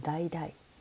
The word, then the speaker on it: だいだい: It's like a small mandarin orange and it's called Dai Dai. で、まあ、一つ目はですね、ダイダイですね。これはまあ小さなおみかんですよね。 And it has the same syllable as Dai Dai. D-A-I-D-A-I. Right? Can you hear the difference? Dai Dai.Dai dai. It's a little bit different tones, right?